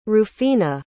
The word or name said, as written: rufina